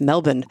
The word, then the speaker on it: Melbourne: Yes, that's how you pronounce it. I've been taught by several people it's Melbourne.